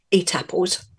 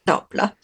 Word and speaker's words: Étaples: So I'm going to start with Étaples, which is spelled E-T-A-P-L-E-S, and lots of Brits pronounce it Étaples, but it's not, it's Étaples, which is close to where I live in the north of France.